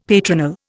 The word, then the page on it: patronal: Indian